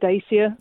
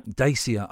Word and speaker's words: Dacia: I always thought it was called Dacia. – Dacia, I know. – Until I've heard the advert.